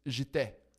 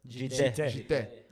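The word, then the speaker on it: Djitté: The way that his name is pronounced is Jite. – Jite. – Musa Jite. You know, it has a D Jite. In front of it, but it's Jite. I think it's like French. – It's French.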